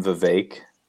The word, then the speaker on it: Vivek: Didn't he say that you pronounce his name Vivek, like cake or oh, something?